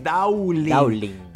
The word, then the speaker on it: Dhawling: Voy a pronunciar el nombre, a deletrear, es D-H-A-W-L-I-N-G, Daulin.